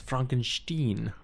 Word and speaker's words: Frankenstein: Frankenstein. – Oh, now you're pronouncing the German on this show.